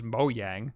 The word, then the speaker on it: Mojang: It's both. It's Swedish, so in Swedish it's Mojang, yeah. But in every other country, they just read it out as Mojang.